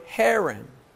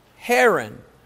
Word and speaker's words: Haran: Sister Helen. rhymes with heron. Who is heron? Anybody know? Paige, who is heron? Huh? The bird? Not an H-E-R-O-N. An H-A-R-A-N. Who is heron?